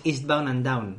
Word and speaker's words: Eastbound and Down: No, me suena de es genial, y además ya lo digo bien, es Eastbound and Down.